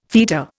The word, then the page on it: feta: Indian